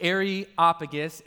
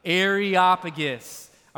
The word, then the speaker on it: Areopagus: So let's now leave the Areopagus, that's how you say it. Let's all just say it together for fun one time. Areopagus.